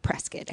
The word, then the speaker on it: Prescott: And apparently it's spelled Prescott, but it's pronounced Prescott.